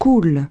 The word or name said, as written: coulent